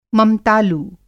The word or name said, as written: ममतालु